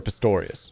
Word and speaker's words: Pistorius: Oscar Pistorius. – Pistorius.